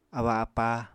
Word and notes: abba-apá: Apatani

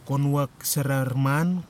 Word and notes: konuwak sarariman: kwamera

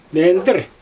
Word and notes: mendIr: Xyzyl

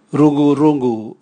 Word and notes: rugurugu: Raga

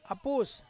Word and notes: apus: Ansus

